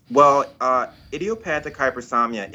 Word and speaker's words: hypersomnia: hypersomnia, right? – That's it.